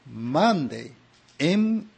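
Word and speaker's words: Maundy: まあ普通はホーリーサースデー、聖なる木曜日というんですが、聖木曜日というんですが、実は戒めという意味のマンデー、 M-A-U-N-D、マンデーと発音するんですね。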